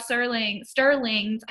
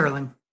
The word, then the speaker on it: Serling: And this is also Rod Sterling's, I think I've been missing. – Serling.